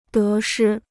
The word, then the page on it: 得失: dé shī